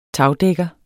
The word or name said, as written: tagdækker